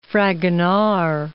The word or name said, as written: Fragonard